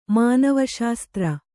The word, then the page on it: ಮಾನವಶಾಸ್ತ್ರ: mānava śastra